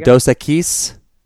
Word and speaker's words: Dos Equis: Dos Equis. – Why are you saying it with the emphasis on the wrong syllable? – Dos Equis. – There you go.